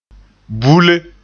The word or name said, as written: bulle